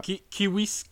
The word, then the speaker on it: civis: Et donc cette phrase c'est civis romanus sum, ce qui signifie... Euh... – kiwis, romanus sum, si on voulait le prononcer ah.